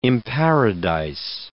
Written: imparadise

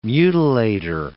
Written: mutilator

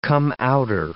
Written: come-outer

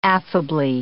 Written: affably

